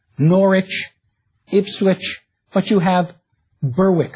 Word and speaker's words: Norwich: Or the suffix which, pronounced without the W now, like Norwich or Harwich, in the Scandinavian settled communities, this ending would have been vik or wick.